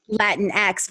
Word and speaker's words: Latinx: and you are doing a Latinx version of that play, right?